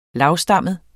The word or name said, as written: LAVSTAMMET